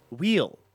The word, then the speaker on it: weal: Wheel. – Wheel. – Wheel. – Without the H. – Without the H, without a couple of E's. Wheel. For those following along. W-E-A-L.